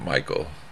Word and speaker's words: Mikl: He pronounces it Michael. – Michael. – He just spells it. Okay. Fancy. – M-I-K-L. – Michael.